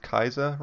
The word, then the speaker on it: Caesar: Caesar is pronounced Kaiser, right? – I've heard that, yeah.